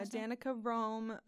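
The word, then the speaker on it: Danica Roem: Is that how you say it? Yeah, Danica Rome, a transgender candidate who won a stunning upset victory against an incumbent Republican 2 years ago for a House seat.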